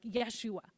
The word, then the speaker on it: Yeshua: That Hebrew pronunciation would be something more like Yeshua.